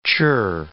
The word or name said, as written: chirr